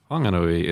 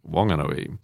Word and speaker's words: Whanganui: The uh, iwi up the uh, Wanganui River uh, spell Wanganui with an H, but it is a silent H. So, for example, the word uh, whanau uh, is spelt with an H, a WH, but it is pronounced up the river Wanau. There is no H. So if you're in the Wanganui region, you don't say Whanganui, it's Wanganui. Um, but outside, you can say whatever you like.